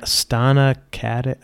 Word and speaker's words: Stana Katic: What is her name? Stanya Katic? – Stana Katic? I mispronounced it.